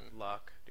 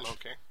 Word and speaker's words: Locke: Dude, it's Locke. – Fuck your couch. – Loki. – Fuck it, it's Locke. – Pretty sure it's supposed to be pronounced Loki. – L-O-C-K-E is named after a philosopher.